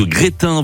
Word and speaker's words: Grestin: Alors ça s'écrit G-R-E-S-T-I-N, mais on prononce pas le S.